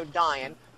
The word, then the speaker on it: Dain: There's also Dain, who I am pronouncing as D-Y-N-E here, in the Iron Hills that Sauron would need to eradicate to have full control of the north.